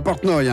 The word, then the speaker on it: Portnov: Ilya Portnov, ça s'écrit I-L-Y-A, plus loin P-O-R-T-N-O-V. C'est pas Portnov, hein, comme l'harmoniciste Harry Portnoy, c'est Portnov. Et vous l'avez entendu tout à l'heure,